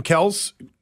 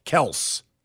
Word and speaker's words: Kelce: And then, by the way, next to Kelce for Jason Kelce, Travis's brother who plays for Philadelphia, they have K-E-L lowercase s, Kelce, not Kelce. – Well, that's how they have the proper pronunciation.